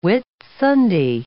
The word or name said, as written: Whitsunday